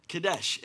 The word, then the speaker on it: Kadesh: He is Jehovah Kadesh. Kadesh. I had to double check it. There's a little thing that like does pronunciations, and it's literally Kadesh.